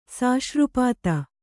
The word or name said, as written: ಸಾಶ್ರುಪಾತ